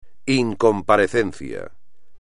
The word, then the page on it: incomparecencia: espagnol